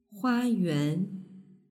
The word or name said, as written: huayuan